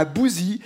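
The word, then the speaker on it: Bousies: Nous sommes dans le département du Nord toute la semaine à Bouzy. C'est un bourg rural de 1800 habitants. Le nom de la commune Bouzy s'écrit B-O-U-S-I-E-S. B-O-U-S-I-E-S.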